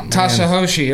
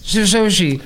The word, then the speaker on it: Satoshi: Arguably could be their Tasahoshi, or whatever how you say it. – That's the Satoshi stacking sats. – Satoshi.